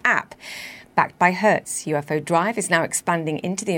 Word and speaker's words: UFO Drive: As you mentioned in the introduction, UFO Drive, as we pronounce it here in Europe, came about from my own personal frustration, having gone through hundreds of poor car rental experiences.